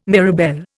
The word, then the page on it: mirabelle: Indian